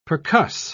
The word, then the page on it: percuss: British English